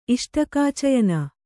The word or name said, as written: ಇಷ್ಟಕಾಚಯನ